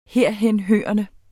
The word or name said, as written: herhenhørende